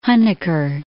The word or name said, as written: Huneker